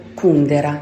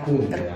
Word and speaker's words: Kundera: Mi ricordo del cancro proprio il cancro che ancora oggi mi ricordo scene e passaggi e poi sicuramente Kundera o Kundera, io non ho mai capito sì. – esattamente quale sia la pronuncia. – Io dico Kundera. Kundera.